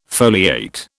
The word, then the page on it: foliate: British English